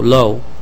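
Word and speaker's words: Lo: And for the committee, my name is spelled S-I-A, first name, last name L-O. – Say that again, please. – L-O, low. – L-O-O. – L-O. – L-O. – One O.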